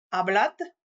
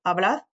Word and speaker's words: hablad: Selon les régions, ce D final sera prononcé comme un D, comme je viens de te le prononcer, ne sera pas prononcé du tout, ou sera prononcé comme un Z un peu atténué, hablad, comed, Vivid.